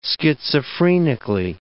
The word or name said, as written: schizophrenically